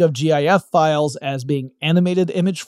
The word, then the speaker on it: GIF: So in honor of his memory, I am going to pronounce the file format the way he said you were supposed to. GIF.